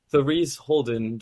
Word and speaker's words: Therese Holden: Today we're with Therese Holden.